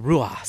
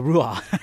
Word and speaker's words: ruach: He breathed the spirit's breath of life, the pneuma, the ruach. Or the ruah, sorry, the ruah. I pronounced it wrong. He breathed the ruah, the pneuma, into Adam.